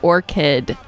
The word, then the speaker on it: orchid: I can't pronounce that word. That is a trick. Okay, Orchard. I never remember it. – Orchid. Orchard. – Orchard for apples. Orchid for White Stripes.